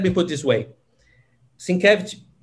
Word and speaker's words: Sienkiewicz: I'll say Sienkiewicz.